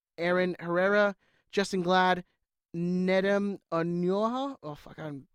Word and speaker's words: Onuoha: O N U O H A. Onyoha. – O N U. – Onyoha. – O H A. – Yeah, Onyoha.